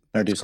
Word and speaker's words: Narducy: It's Narducy, right? That's Narducy. Correct. – Yeah, it used to be CCI a few generations back, so I tend not to correct people.